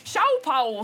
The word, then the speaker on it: Liu Xiaobo: Eller Chao Pao, som det står på internett, hvis du søker etter uttale.